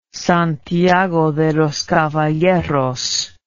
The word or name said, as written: Santiago de los Caballeros